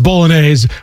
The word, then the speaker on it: Bolognese: It's pronounced Bolognese, but my man Bolognese? – Joe was mispronouncing it as much as I love Joe, but I was like, okay, it is Bolognese, but I don't know exactly what Joe was.